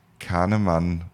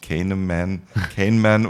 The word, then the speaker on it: Kahneman: Der, der Psychologe David Kahneman, so wird es in Deutsch ausgesprochen, Englisch, weiß nicht, ob er sich Kahneman oder sowas spricht, der hat ein Buch geschrieben, gab es den Wirtschaftsnobelpreis, Schnelles Denken, Langsames Denken.